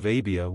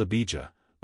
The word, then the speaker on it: Abia: The course of Abia was the eighth. In the Old Testament it is called Abijah, but it would be pronounced as it is spelled here in Luke.